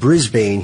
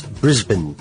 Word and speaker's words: Brisbane: While Americans always pronounce it Brisbane, he says, it's Brisbane.